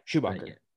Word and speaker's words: Schumacher: Yeah, Schumacher.